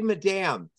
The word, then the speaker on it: Madame: I was going to ask if it was Madame or Madam. – Oh, I say Madame.